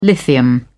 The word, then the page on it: lithium: British English